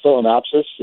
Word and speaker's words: Phalaenopsis: I'm a first-time orchid grower, and I had a beautiful, and I'm not even sure how to pronounce it correctly, but Phalaenopsis, is that close? – Yeah, it's very close.